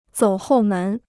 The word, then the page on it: 走后门: zǒu hòu mén